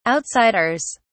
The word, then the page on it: outsiders: British English